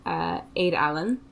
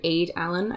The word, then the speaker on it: Aed Allan: It depends on your Irish pronunciation. – Is that two words? – Two words. A-E-D space A-L-L-A-N. So Allan is the same, but it's along the lines of Aed Allan, I think.